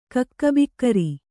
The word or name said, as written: ಕಕ್ಕಬಿಕ್ಕರಿ